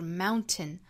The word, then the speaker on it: mountain: 这句话里有两个单词包含了一个鼻腔爆破的发音技巧，一个就是山这个词，就是 mountain。那如果用鼻腔爆破的发音方式发出来呢，这个词应该发 mountain。